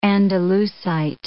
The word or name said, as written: andalusite